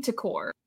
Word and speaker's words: Tikor: I was thinking Tikor. – Okay. Like it rhymes with decor.